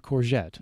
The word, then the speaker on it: courgette: Yes, ask for a courgette. – Yes, not a zucchini.